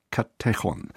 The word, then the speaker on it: Katechon: Der Katechon des Björn Höcke ist eben richtig ausgesprochen der Katechon.